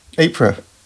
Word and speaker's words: Ypres: Ypres.